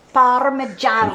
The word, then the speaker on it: Parmigiano: Parmigiano, do you roll it? – Parmigiano. I'm teaching my son how to roll the R. – Yeah. Oh, really? How's he doing with that? – Parmigiano.